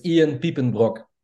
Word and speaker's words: Piepenbrock: Oh, dude, that you butchered that. – How do I say it in the Dutch? – It's Ian Piepenbrock.